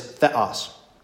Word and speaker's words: theos: But like the first one, you see it, is theos. So it's two syllables.